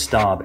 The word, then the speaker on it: Staub: Nope, it's Staub. S-T-A-U-B.